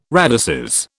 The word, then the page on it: radices: British English